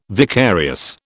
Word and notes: vicarious: British English

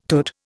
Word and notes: tut: Indian

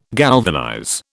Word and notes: galvanize: British English

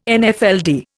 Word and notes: Nfld: Indian

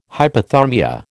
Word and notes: hypothermia: American English

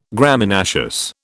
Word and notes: Graminaceous: British English

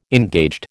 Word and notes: engaged: American English

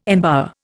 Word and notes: embower: Indian

